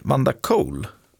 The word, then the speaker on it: Vanda Cole: Vanda Cole. Uttalar man så? – Ja, det skulle jag spontant säga.